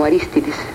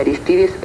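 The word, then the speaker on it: Arístides: Señor presidente, confieso que en las primeras informaciones que yo he mandado a México, yo mencionaba su nombre como Aristides. Ahora que sé que en Panamá se pronuncia correctamente Aristides, prometo no volver a cometer esa equivocación.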